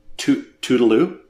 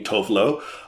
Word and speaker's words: Tove Lo: and she says that the pronunciation of her name is like saying Tuvalu, or yeah, like Tuvalu kind of thing. She has completely embraced that the pronunciation outside of Sweden being Tove Lo, and she didn't feel like correcting the entire world because I mean it's just her stage name too, right?